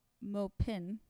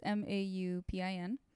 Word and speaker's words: Maupin: Or it's spelled in English Maupin, but you just wouldn't pronounce it that way in French. – Mm-hmm. – Just in case folks are wondering, it's Maupin.